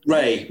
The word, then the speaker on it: Rei: So a song like Rhea, is that how I pronounce it? R-E-I? – Ray. – Okay, Ray. You literally came up with that.